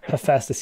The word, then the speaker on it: Hephaestus: And that was Hephaestus. Am I saying that right? – Hephaestus.